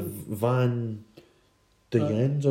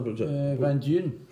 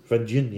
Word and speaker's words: Van Duyn: The Van Duyens, or Van Duyn. – Van Duyn, yeah. You need a wee bit of a Dutch accent in that as well, the Van Duyn.